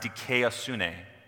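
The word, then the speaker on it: dikaiosune (δικαιοσύνη): And the word righteousness there in the beginning, in its Greek form, it's pronounced dikaiosune.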